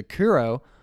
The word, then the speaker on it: Kuro: Am I saying that right? – As far as I know, yeah. Kuro, yeah.